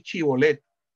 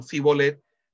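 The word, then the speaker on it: chibolet (שיבולת): Entonces, si no podían, no podían decir chibolet, sino cibolet, sabían que era, de, eh, era eh, una pronunciación propia de los, de los efraimitas. Eh, esa pronunciación de, de, la chin por la sin era,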